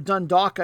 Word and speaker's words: Dundalk: Puts them between Dundalk of Ireland, or Dundalk, I should say, L is silent, and Sion of the Swiss Super League.